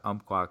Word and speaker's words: Umpqua: So you are the head obstacle course racing coach at Umpqua Community College.